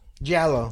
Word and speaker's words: giallo: is it pronounced Giallo? – Giallo.